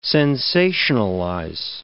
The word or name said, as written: sensationalize